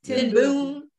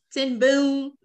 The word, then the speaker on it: ten Boom: Corey Ten Boom. Did I say that right? – Yeah. – Ten Boom. Okay. Mm-hmm. Ten Boom.